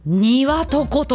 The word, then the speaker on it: 接骨木: 接着剤の接に骨、ボーンの骨にトゥリーの木、接着剤の接に骨に木で何と読むでしょう。庭とこと読むらしいです。